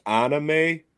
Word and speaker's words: anime: In Japan, so why people it's actually anime. Pronounced anime. – Anime.